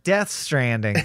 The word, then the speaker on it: Death Stranding: That's right, Death Stranding, because every character pronounces it with that emphasis.